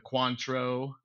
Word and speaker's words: Cointreau: Which yeah, we learned about triple sec replaces all those really fancy orange ones like the Cointreau.